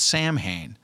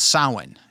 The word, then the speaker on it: Samhain: So they're kind of celebrating it, and they had a name for it that I always called Samhain. Because it's actually spelled that way. But in truth, it's called Samhain, is how you pronounce it.